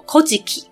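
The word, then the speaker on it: 古事記: 古事记，它念作 Kojiki。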